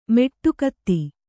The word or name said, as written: ಮೆಟ್ಟುಗತ್ತಿ